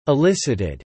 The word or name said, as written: elicited